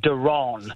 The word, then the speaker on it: Daron: So, yeah, it's Darren, but it's spelled D-A-R-O-N. Okay. So I get called Daron.